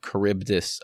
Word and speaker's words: Charybdis: We're going to be talking about Charybdis and Scylla or Scylla. I've seen it, but Charybdis, I think, is the way to pronounce.